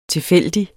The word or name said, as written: tilfældig